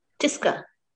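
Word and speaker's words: Tisca: Is it Tiska or Tishka? – Tiska. – Tiska? Tiska Chopra.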